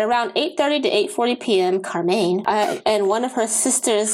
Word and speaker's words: Karmein: This often left their oldest daughter, 13-year-old Carmen, I think I'm saying that right, K-A-R-M-E-I-N, or Carmaine, Carmaine, like low main.